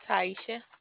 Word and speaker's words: Taeichia: Taisha. – How do you spell that? – T-A-E-I-C-H-I-A.